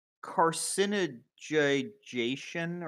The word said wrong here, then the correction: carcinization: How is it pronounced? – Carcinization. – Carcinization.